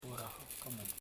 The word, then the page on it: kurakamung: ku.ra.ka.mung